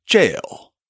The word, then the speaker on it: gaol: If you look in the show notes this week, you'll see a word spelled G-A-O-L, which looks like it should be pronounced gaol. In fact, in an old episode, that's exactly how I pronounced it, before I figured out that that's just the old spelling of our word, jail.